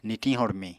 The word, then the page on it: nitiñ-hormiñ: Apatani